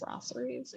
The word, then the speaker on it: brasseries: Brasseries.